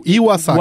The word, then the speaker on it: Iwasaki: Iwasaki, eu nunca perguntei pra ele quando o sobrenome dele se pronuncia. – É o, I, Iwasaki. Iwasaki. Iwasaki, Iwasaki né? I, Iwasaki, tá.